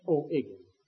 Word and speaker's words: O'Higgins: And then in 1818, a fellow by the name of Bernardo O'Higgins, as they say it there, O'Higgins. H's don't get pronounced.